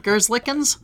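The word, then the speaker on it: Merzlikins: Is that how you say his name? Gerzlikens? – Sure.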